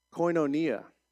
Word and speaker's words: koinonia: They devoted themselves to fellowship, which is, it's pronounced koinonia.